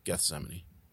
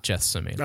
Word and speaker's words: Gethsemane: And number one, with a bullet, the big showstopper, the intermission song, Gethsemane. – There you go. Not Gethsemane.